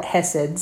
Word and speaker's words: chesed: So basically, guys, if you don't know what this word is, again, it's C-H-E-S-E-D. If you Google it, you can look it up that way. It is a Hebrew word, and it's actually pronounced chesed, like with kind of a German-type growl on the front of it.